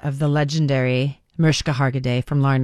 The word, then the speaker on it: Mariska: She said, by the way, she likes her name, even though it's spelt with an I, she refers to herself as Mariska.